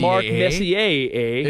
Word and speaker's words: Messier: Hey, in Canada, how do they pronounce like legends like Mark Messier? Is it like Mark Messier? – Oh, they say Mark Messier.